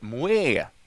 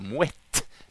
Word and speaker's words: Moët: En vanlig missuppfattning är att den korta benämningen Moët uttalas Moët, likt franskan, då, men den stavas ju med T på slutet. Men det korrekta uttalandet är alltså Moët, med hårt T.